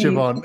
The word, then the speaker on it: Siobhan: And for us Australians that struggle with Siobhan, it's spelled S-I-O-B-H-A-N. – Yes, pronounce Siobhan.